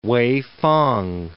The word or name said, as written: Weifang